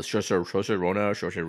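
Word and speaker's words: Saoirse: The Shosha Rina. – Rhymes with inertia.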